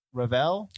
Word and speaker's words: Revell: Music by Graham Revell. – That's how I've always pronounced it.